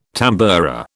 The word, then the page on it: tamboura: British English